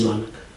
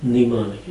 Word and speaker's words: mnemonic: Mnemonic. How do you say it? – Mnemonic. – Mnemonic device. M-N-E. Right. There's an M there, but we don't pronounce it, right? – You have to remember that.